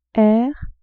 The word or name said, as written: r